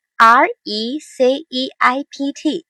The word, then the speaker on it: receipt: receipt 这个词有一点需要我们大家注意，就是它的发音中省去了 p 的发音，它的拼写是 r e c e i p t，但是大家已经注意到这个 p 是没有发音的，读作 receipt，所以也请大家留意。